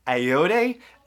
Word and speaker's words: Ayoade: And also the droid is played by, oh, here we go with pronunciation, Richard Ayoade, A-Y-O-A-D-E.